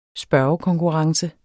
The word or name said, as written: spørgekonkurrence